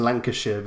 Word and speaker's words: Lancashire: So coming up next, we have Gromit versus Snoopy in, am I going to say this right, Hellfire in Lancashire? – Yeah, it's funny because it's really pronounced Lancashire, but I think we always knew these titles were going to get a little more grasping as we went on.